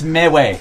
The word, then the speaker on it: MeWe: It's pronounced MeWe. – MeWe.